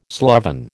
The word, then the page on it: sloven: American English